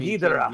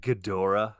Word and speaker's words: Ghidorah: You know there's people that pronounce it Ghidorah? – Who? – I know. – It's awful.